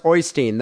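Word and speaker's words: Øystein: His name is pronounced Oystein. The O is phonetically the same as the middle sound in Lerv, so it's Erstein. And the Erstein. Y is pronounced like the first sound in you.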